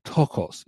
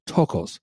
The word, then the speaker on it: ptochos: The word "poor" in that text is the Greek noun tokos. It's spelled P-T-O-C-H-O-S, tokos, which denotes those who are destitute of wealth, influence, position, power, those who are afflicted and helpless.